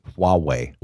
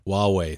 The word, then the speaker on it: Huawei: And that sounds great, but when you look at especially Blue and is it Huawei? How do you pronounce it? – Huawei. – Huawei, thank you.